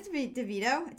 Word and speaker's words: DeVito: No, is it DeVito? Did I say it wrong? – You said DeVito. – It is. Yeah.